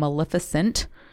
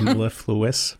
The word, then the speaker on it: mellifluous: And you have no idea how long I practiced that word and how long it took me to stop saying maleficent. – Instead of mellifluous.